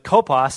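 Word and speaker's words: kopos (κόπος): But kopos sounds like the word copious.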